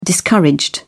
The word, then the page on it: discouraged: British English